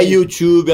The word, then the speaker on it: YouTube: E é YouTube a pronúncia. – Agora já veio de sacanear. – Não, sempre, porque as pessoas aqui, elas costumam brasileirar as palavras.